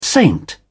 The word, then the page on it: saint: British English